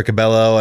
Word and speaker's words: Cabello: Always throws me off to put L's in the Cabello. – Yes. People will say her name wrong all over the globe. – Because it's Cabello or whatever. – Yeah, Cabello for Cabello. And I've heard Camila. It's Camila Cabello.